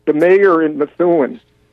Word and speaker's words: Methuen: Methuen is the proper pronunciation.